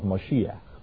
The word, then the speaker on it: Mashiach: When you say Messiah, that's an adulterated form of the Hebrew pronunciation of Mashiach.